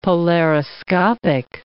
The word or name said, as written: polariscopic